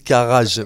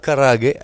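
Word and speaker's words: karaage: C'est les, Français, disent garage, mais on dit euh, karage avec le. J'ai quelques notions de, japonais euh, via ma femme, mais euh, karage, donc où j'ai switché le le, poulet avec le chou-fleur.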